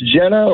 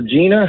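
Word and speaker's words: Genna: It's Jenna or Gina, however you want to say it, spelled with a G, G-E-N-N-A.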